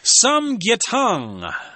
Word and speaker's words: samgyetang: You could also warm up with a big hearty bowl of samgyetang. – Yeah. – Okay, I hope I said that right. – That's how I said it.